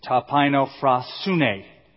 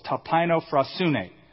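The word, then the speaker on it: Tapaino phrasune (ταπεινοφροσύνη): Tapaino phrasune. Six syllables. Tapaino phrasune.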